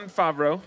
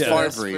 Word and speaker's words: Favreau: It's Favreau. For sure. That's, you're thinking of Brett Favre.